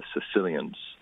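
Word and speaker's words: caecilians: called the Sicilians. Not from Sicily. It's spelt differently.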